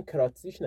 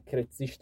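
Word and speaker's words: Krätzig: کرتزیش اشتباه بعضی جا هم نوشتن کراتزیش نه کرتزیش تلفظ درستشه کرتزیش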